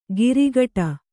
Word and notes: ಗಿರಿಗಟ: girigaṭa

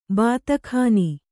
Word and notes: ಬಾತಾಖಾನಿ: bātākhāni

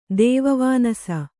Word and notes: ದೇವವಾನಸ: kannada

